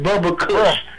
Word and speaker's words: Bubba Kush: He called it Bubba Kush. So it's pronounced B-U-B-B-A Kush. Not Bubble.